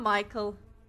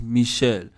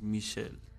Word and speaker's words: Michel: Not Michael. Not a boy. – No, it's Michel. Oh. Not Michel. French pronunciation is Michel.